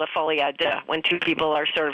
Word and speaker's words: folie à deux: Yeah, folie à deux.